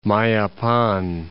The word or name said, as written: Mayapan